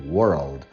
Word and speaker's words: world: Yes, I know, Virginia, for those of you learning English as a second foreign language, it's more difficult to pronounce the word world, but it's just a matter of practice. Imagine that you have the word were plus old, and you say it all together, world.